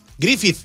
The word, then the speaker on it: Griffith: Como Griffith. – Griffith. – Cada vez que ponga la TH como D, como THE. – A ver, ¿cómo se dice, pues? – Griffith.